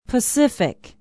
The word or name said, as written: pacific